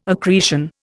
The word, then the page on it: accretion: Indian